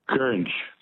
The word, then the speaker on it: goringe: The word that rhymes with orange is gurnge. – Is what? Gurnge? – Gurnge, yep. – What's the spelling of gurnge? – G-O-R-I-N-G-E.